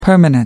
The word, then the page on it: permanent: American English